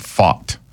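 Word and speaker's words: Pfaadt: The guy they got pitching tomorrow night is his name is spelled P-F-A-A-T or A-A-D-T. It's pronounced fought or fought, fought or fought.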